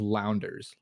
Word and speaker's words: Launders: I believe their tag is pronounced Launders. Launders? They're based at L-A-U-N-D-E-R-S, Launders.